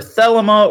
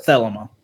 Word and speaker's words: Thelema: So in addition to all that, Crowley was also the founder of a spiritual movement known as the Church of either Thelema or Thelema. I've heard it pronounced both ways. I'm going to pronounce it Thelema.